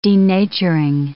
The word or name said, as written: denaturing